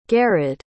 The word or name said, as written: garrad